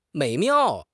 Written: 美妙

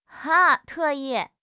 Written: 特意

